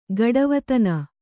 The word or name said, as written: ಗಡವತನ